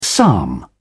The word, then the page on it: psalm: British English